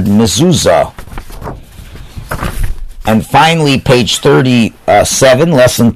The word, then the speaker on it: mezuzah: That's a mezuzah, but it's pronounced mezuzah.